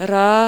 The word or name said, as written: र